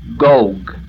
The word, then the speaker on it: Gog: The word Gog is the Hebrew word or root word gigantic, leaving out the O, G-G, or Gog, long O. The word then is pronounced Gog, meaning gigantic, or gigantic land, or nation.